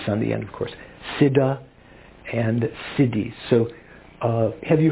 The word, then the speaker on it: Siddha: Siddha, S-I-D-D-H-A, that's a pretty good rendition of the Sanskrit.